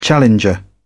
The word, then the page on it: challenger: British English